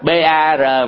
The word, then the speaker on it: BAR: tức là chữ B bò ha, chữ A này nè, A lớn này với chữ R cái rổ á, BAR đọc là chữ ba, chữ ba đó tiếng Anh đó là cái thanh xà đó, cái xà cái cây rồi đó là ba,